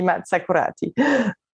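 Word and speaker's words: Massa Carrara: Italian, it would be Maza Karate.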